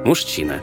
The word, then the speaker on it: мужчина: Сейчас в русском языке масса буквенных сочетаний, которые произносятся как «ща». Например, «СЧ» – «счастье», «СТЧ» – «жёстче», «ЗЧ» – «резче», «ЗДЧ» – «объездчик», а также «ШЧ» – «веснушчатый» и «ЖЧ» – «мужчина».